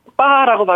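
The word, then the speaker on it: 빠라고 (八): 예를 들면 8은 빠라고 발음하는데 이것은 중국어로 파차이, 파차이 하면 돈 벌다 부자가 된다는 뜻이거든요. 중국 사람들 돈 버는 거 정말 좋아하지 않습니까? 네. 그래서 이 빠, 팔이라는 빠의 발음이 파차이의 파 발음이 비슷하다 약간 그 팔이 인기가 있고요.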